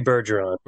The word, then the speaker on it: Bergeron: Oh, Matthew Bergeron.